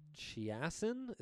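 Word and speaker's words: Chaisson: I kind of felt like that for Chiasson. Is that how I pronounce it, Hank? – Yeah.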